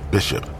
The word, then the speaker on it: BZSHP: The same artist who went by the name BZSHP, pronounced Bishop, painted other murals throughout Oakland.